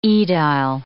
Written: aedile

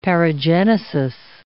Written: paragenesis

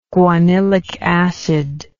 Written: guanylic acid

